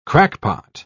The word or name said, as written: crackpot